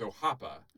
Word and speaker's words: Orapa: and it's spelled O-R-A-P-A. And I said to him, I'm like, oh, thank you. Oh, I really like that album you got by Orapa. And he's like, oh, no, it's Ojapa.